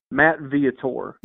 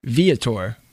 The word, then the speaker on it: Viator: Matt Viator. – Viator.